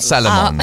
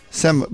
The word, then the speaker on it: salmon: Not Salomon. Salomon is more orange in it. – Why do people not say the L? – Salmon.